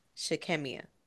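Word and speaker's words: Shy'Kemmia: In a nation where the threads of hope can fray under the weight of statistics, the story of Shy Kem Mia. Shy Kem Mia. I hope I said that right. It's Shy. Apostrophe K E M M I A Shikemia Pate. Shikemia Pate.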